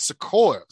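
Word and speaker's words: Sikoa: Uh, we open the show with Solo Sequoia versus uh, I gotta say that right. It's Sequoia, Sequoia, Sequoia.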